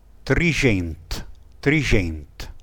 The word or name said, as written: trisgent